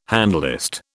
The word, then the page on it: handlist: British English